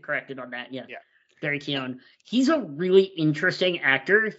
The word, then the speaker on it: Keoghan: I believe it's Keoghan, yeah. – Yeah, it is Keoghan.